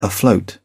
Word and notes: afloat: English; British English

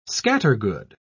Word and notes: scattergood: British English

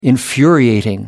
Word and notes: infuriating: American English

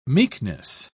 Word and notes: meekness: British English

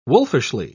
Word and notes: wolfishly: British English

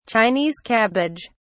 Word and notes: chinese cabbage: British English

